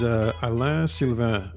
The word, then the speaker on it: Alain Sylvain: My name is Alain Sylvain, is how you say it in French.